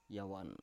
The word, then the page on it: yawan: Biak